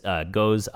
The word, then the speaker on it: gose: The easiest way that I've seen it kind of explained is gose. And it's, you can kind of think of it as like in a phonetic spelling of like G-O-E-S, like goes, like goes somewhere.